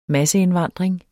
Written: masseindvandring

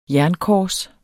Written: jernkors

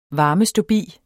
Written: varmeståbi

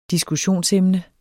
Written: diskussionsemne

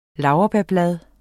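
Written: LAURBÆRBLADE

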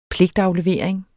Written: pligtaflevering

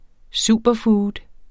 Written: superfood

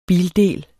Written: bildel

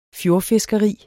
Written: fjordfiskeri

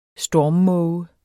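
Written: STORMMÅGE

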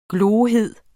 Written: glohed

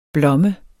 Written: blomme

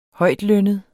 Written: højtlønnet

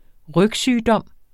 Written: rygsygdom

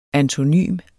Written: antonym

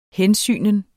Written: hensygnen

